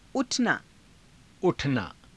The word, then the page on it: u_thanaa: Hindi